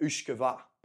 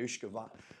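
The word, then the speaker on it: uisce beatha: And in Gaelic, or Gaelic as the Irish call it, we pronounced it Uishkava. Okay, Uishkava.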